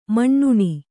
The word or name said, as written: ಮುನ್ನಿ